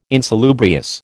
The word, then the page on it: insalubrious: American English